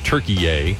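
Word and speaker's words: Türkiye: They're calling it Turkey-yay, but I guess he's doing the official pronunciations.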